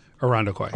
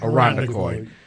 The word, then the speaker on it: Irondequoit: How do you pronounce that? – Irondequoit. – Irondequoit.